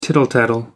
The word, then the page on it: tittle-tattle: American English